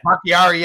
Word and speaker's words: Bakhtiari: How do I say his name? For the Packers, the left tackle, David Bakhtiari. – Bakhtiari. – Yeah, there you go.